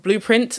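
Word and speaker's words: Bleuprint: I launched a brand new business called Blueprint. So that's spelled the French way, B-L-E-U, print.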